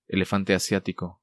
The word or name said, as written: Elefante Asiatico